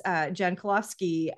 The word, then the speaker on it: Jankulovski: And the way to say your last name is Jan Kulovsky, but it's spelled Jankulovski.